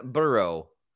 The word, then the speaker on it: burro: Say burro, like brrr with R.